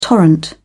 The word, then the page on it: torrent: British English